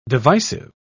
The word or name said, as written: divisive